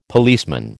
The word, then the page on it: policeman: American English